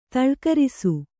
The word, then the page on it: ತಳ್ಕರಿಸು: taḷkarisu